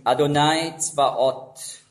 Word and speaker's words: Adonai Tzvaot: Auf Hebräisch ausgesprochen Adonai Tzvaot, beziehungsweise so wie es im Judentum nicht ausgesprochen wird, aber wörtlich da steht, Yahweh Tzvaot, eben der Ewige der Heerscharen.